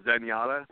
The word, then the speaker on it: Zenyatta: Uh, it's called Zenyatta. It's spelled with a Z, like Zen, and then Yada, just one word.